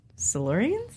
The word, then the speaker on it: Silurians: Silurians? – That sounded right.